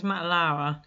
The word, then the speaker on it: Lauer: Whereas Matt Lure, I don't really recognise. – Lauer? – Lauer, is that how you say it? – I think it was Matt Lauer.